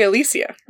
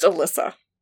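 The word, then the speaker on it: Alysia: Because I say Alicia, right? Yeah, that's how it's spelled. Right. It's pronounced Alyssa.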